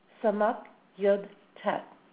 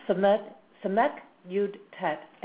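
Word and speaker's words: Samak Yud Tet (סיט): Now, the way that this name is pronounced is Samak Yud Tet. Okay, that's Samek Yud Tet, and the common name is Miracle Making.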